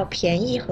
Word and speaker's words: 便宜: 右边，你看我们说到便宜和方便，又是一组多音字啦。